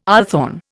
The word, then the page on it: althorn: Indian